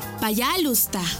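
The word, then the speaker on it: пожалуйста: Y se dice payalusta. ¿Cómo? Payalusta. Una vez más. Payalusta. Y se escribe payalusta. P-A-Y-A-L-U-S-T-A.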